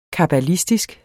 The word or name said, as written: kabbalistisk